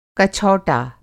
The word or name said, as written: कछौटा